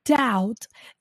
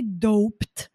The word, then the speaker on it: doubt: So doubt, you notice when you spell this word, you notice that we don't say doped. In other words, we don't pronounce the B. The B is silent here, right?